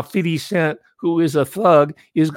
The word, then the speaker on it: 50 Cent: It says 50 Cent, but we pronounce it 50. We drop the F. That's part of the Ebonics, so I'll say 50, 50 Cent.